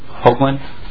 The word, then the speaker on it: Hoogland: My name is Tim Hoogland. – Hoogland? – H-O-O-G-L-A-N-D.